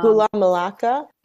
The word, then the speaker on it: Gula Melaka: So you have this, I'm sorry, I'm going to mispronounce the Galama. – Gula Melaka.